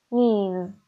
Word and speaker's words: Ms: تفاوتشون در نحوه تلفظشونه. حالا از شما میخوام که دستتون رو بذارید روی گلوتون و لغت نیس رو تلفظ بکنید. نیس، نیس. حالا میز رو تلفظ بکنید. میز، میز.